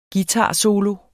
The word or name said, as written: guitarsolo